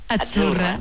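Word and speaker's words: Azzurra: La cita no es la cita como decimos en catalán tan sonora, no. Es Achurra. – Muy bonito. – Es como pizza.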